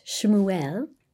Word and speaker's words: Shmuel: And even the name Samuel, pronounced Shmuel, is made up of the two words Shem, which means name, and El, which means God.